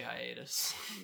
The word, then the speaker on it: hiatus: If it was just spelled exactly like that, the French phonetical pronunciation would be hiatu. – Just saying, this is an American podcast. In America, we say hiatus.